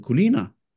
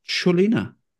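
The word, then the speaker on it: Čolina: Fordi jeg troede jo, han hed David Kuliner. Det er ikke sådan, det udtales, fordi det er jo specielt C. Jeg ved ikke, hvad det C det hedder. Øh, men det udtales åbenbart Cholina.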